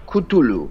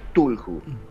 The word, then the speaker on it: Cthulhu: como los mitos del Tulhu o del Kutulu eh, Es difícil de pronunciar ¿no? – o, Tulhu. Se escribe C-T-H-U-L-H-U.